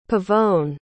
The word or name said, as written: Pavone